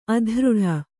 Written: ಅದರಟ್ಟು

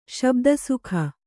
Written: ಶಾಬ್ದಿಕ

